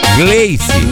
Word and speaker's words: Gleyce: É, eu acho que eu tava falando errado, eu tava pronunciando com R. É Glei, Glei, Glei, Glei, G-L-E-Y-C-E, Gleice.